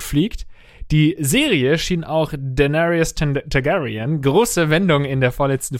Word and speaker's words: Daenerys Targaryen: Wie findest du, habe ich Daenerys Targaryen ausgesprochen als Fantastisch.